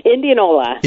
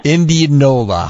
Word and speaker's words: Indianola: Indianola. – Indianola.